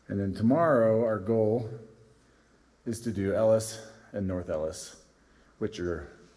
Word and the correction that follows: Eolus: It's actually Eolus, right? I was saying it wrong, Ellis.